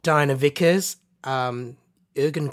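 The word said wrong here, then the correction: Eoghan: Quick. Yeah. Is that how you say his name? – Owen. – Is it Owen? – Yeah, it's Irish.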